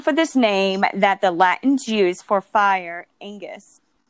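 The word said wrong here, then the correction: ignis: I think I'm saying this wrong. I think it's not Angus. I have to edit this. Okay. Fire, Ingus, and it is the root word of the English word that got the words ignite, ingenious, and ignition.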